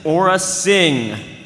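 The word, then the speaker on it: Aurra Sing: The official pronunciation for our next character is Aura Singh.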